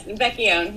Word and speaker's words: Vecchione: Vecchione, yes.